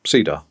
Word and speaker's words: CEDAR: So he had C hyphen DAR, mm-hmm. – obviously pronounced CEDAR. Um, so they looked for a word uh, to put the E in so that it could be cedar, and they chose enhanced.